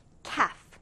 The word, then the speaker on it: calf: 이 단어는 발음을 주의해주세요. 이때 L 발음이 나지 않아요. 그래서 calf. calf라고 발음하는데 이 단어는 두 가지 뜻이 있어요.